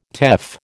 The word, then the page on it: teff: American English